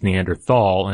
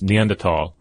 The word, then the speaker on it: Neanderthal: Yeah, the Neanderthal, which is typically spelled, I mean, it's spelled like it's Neanderthal, and I've been saying it wrong for ages, but of course, as you pointed out, it comes from the German, so it's Neanderthal.